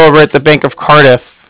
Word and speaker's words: Cardiff: With the Bank of Cardiff. And Cardiff is spelled C-A-R-D-I-F-F, as in Frank, so it's like car and different.